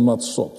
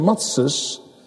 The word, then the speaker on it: Matzes: Laten we nou nog eens nader kijken, zoals dat ook in de volgende versen gebeurt, in hoofdstuk 12, naar dat feest van Matzot. Wij zeggen Matzes, en dat is de Askenazische uitspraak van het woord.